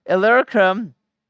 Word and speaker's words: Illyricum: Illyricum, I have fulfilled the ministry of the gospel of Christ. How far was Jerusalem to Illyricum?